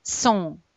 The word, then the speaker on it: sont: Теперь смотрите, T не читается. ON – это носовой звук, поэтому в нос sont.